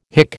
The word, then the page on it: hic: American English